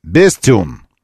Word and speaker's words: Bestune: Бренд называется Bestune. Как бы best, лучший. А юн это как, ну, как юн, Bestune, вот и все.